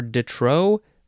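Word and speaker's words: de trop: Detro. That's this word. It's two words, D-E and then T-R-O-P. There's a P in there, but you pronounce it detro.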